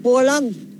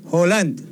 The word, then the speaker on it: Haaland: Es con doble, como una doble O, ¿no? – Haaland. – Haaland.